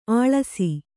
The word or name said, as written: ಅಳಸು